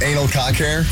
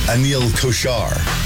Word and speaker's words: Kochhar: As I told you about the North Carolina State's commencement speaker, the guy's name was Anal Cock Hair.